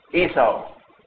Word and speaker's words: Esau: Esau. – Okay? I know how to pronounce it in Spanish, but no, I was not sure about in English. Okay, Esau.